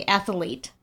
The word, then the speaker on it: athlete: I say athlete. I don't know how you say it. – Athlete. – But I kind of like it when people say athlete. – Athlete, like putting the extra A in there.